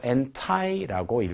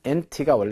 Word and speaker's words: anti: 자, anti라고도 읽고 anti라고 읽기도 합니다. 근데 저는 anti-aging 이것이 좀더 저에게 좀 익숙한 것 같아서 저는 그렇게 읽을 거고요. anti-aging은 그러니까 뭐예요? 노화 방지가 되겠죠. anti가 원래 반대의 뜻이거든요.